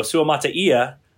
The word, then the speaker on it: Suamataia: So Suamataia,